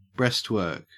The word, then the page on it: breastwork: Australian English